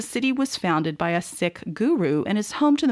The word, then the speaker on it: Sikh: As a side note, I read up on it, and the Sikh community prefers this pronunciation to Sikh, which I frequently heard in the past, so I'm going with Sikh.